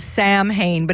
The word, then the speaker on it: Samhain: Samhain is spelled how it looks is Samhain, but it's pronounced Samhain.